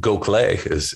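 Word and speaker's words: Gokhale: And if you look at the work of Esther Gokel, and who Gokle is how you pronounce that last name,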